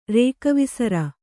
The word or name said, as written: ರೇಕವಿಸರ